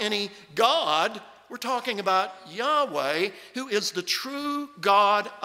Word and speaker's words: Yahweh: scholars have concluded that the original pronunciation of the tetragrammaton was Yahweh.